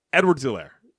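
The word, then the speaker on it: Edwards-Helaire: He almost paused between Edwards and Elaire. Edwards Elaire. Edwards Elaire. That's it. And he went Edwards Elaire.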